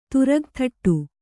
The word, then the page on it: ತುರಗಥಟ್ಟು: Kannada